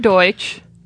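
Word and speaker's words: Deutch: It's Howard Deutsch. Am I pronouncing it right, I think? – Close enough.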